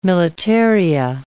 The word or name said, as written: militaria